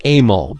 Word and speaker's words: Emil: I think it's pronounced Emil, but it's E-M-I-L.